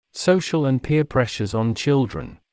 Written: social and peer pressures on children

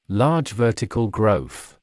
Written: large vertical growth

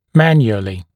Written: manually

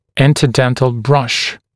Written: interdental brush

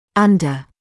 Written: under

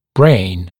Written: brain

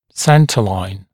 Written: centre line

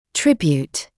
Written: tribute

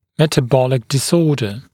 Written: metabolic disorder

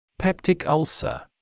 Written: peptic ulcer

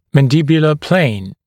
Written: mandibular plane

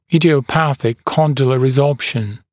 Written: idiopathic condylar resorption

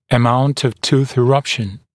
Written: amount of tooth eruption

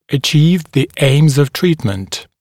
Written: achieve the aims of treatment

